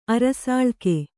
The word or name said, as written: ಅರಸಾಳಿಕೆ